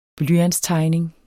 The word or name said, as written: blyantstegning